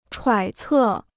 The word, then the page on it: 揣测: chuǎi cè